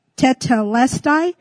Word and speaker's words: tetelestai: And so the Greek word for "it is finished" is, it's, I can't say it right, but it's tetelestai. It's T-E-T-E-L-E-S-T-A-I.